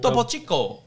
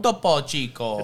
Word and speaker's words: Topo Chico: Yeah, it's a Topo Chico. – Topo Chico? – Topo Chico.